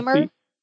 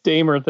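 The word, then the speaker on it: Damer: You're using, by the way, the German pronunciation of my last name, but it's actually Dahmer. – It's Dahmer, the British, but there's also a French Canadian.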